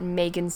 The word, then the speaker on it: Maegan: If you would like to support me on Patreon, find me at Maegan C Creates, and my name is spelled M A E G A N.